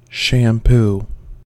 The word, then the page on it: shampoo: American English